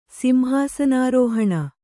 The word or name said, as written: ಸಿಂಹಾಸನಾರೋಹಣ